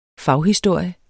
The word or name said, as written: faghistorie